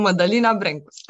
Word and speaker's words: Madalina Brencus: This is Madalina Brencus, and she's going to say her name totally different because she's from Romania. Hi, Madalina. – Hi, I'm Madalina Brencus.